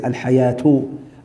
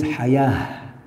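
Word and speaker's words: Haya (حياة): Al-Haya, we say Haya, we don't pronounce the T at the end unless there is a context, like if there is another word after it, then we may say Al-Hayatu, Al-Hayatu Al-Ayiba. All right? But here Allah says Haya. T still ta marbota, but we don't pronounce it haya.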